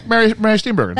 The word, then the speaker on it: Mary Steenburgen: Mary Steenburgen.